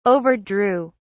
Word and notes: overdrew: British English

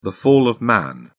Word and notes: fall of man: British English

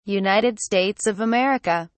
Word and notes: United States of America: British English

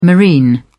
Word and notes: marine: British English

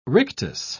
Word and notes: rictus: British English

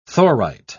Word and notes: thorite: British English

